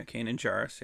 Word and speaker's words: Kanan Jarrus: Jarrus, is that how you pronounce it? – Kanan. Yeah, Kanan Jarrus.